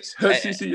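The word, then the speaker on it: Hutchinson: So you started your career at, is it Hutchison? Is that how you say it? – Yeah, Hutchison, yes, sir.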